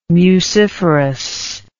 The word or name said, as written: muciferous